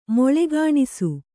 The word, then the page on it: ಮೊಳೆಗಾಣಿಸು: moḷegāṇisu